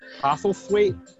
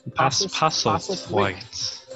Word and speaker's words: Postlethwaite: How do you pronounce that? – Postlethwaite. – Postlethwaite. – Postlethwaite. Pete Postlethwaite.